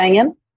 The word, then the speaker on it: Engen: I think it's Ingrid Syrstad Engen, we say in Norwegian.